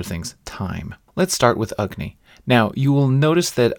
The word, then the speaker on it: Agni: They say that the short A, so the A that's in Agni but not in Kali, is pronounced like the vowel in the word but. So basically, it's like a almost like a schwa.